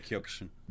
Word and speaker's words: Kyokushin: I don't know what you guys pronounce that different. Kyokushin? – We pronounce it Kyokushin.